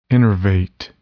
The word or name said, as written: innervate